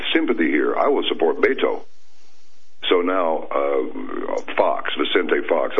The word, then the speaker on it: Beto: It's pronounced Beto.